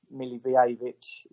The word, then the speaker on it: Milivojevic: But I think Kiyate, Jimmy Mack and Luka, Milivojevic is how you pronounce it, Jay.